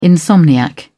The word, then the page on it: insomniac: British English